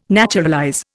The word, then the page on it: naturalize: Indian